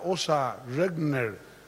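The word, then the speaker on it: Åsa Regnér: Señora Osa Regner, así me dijeron que se pronuncia, directora general adjunta de la UNESCO.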